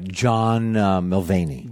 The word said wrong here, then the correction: Mulaney: John Mulaney.